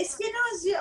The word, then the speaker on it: Eskenazi: Bu bahsettiğiniz Roza Eskenazi'nin e, Eşkenazi mi Eskenazi mi doğru telaffuz ediyor muyum? – E, Eskenazi aslında e, yani e, literatürde Eskenazi olarak veriliyor.